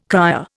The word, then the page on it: cryer: Indian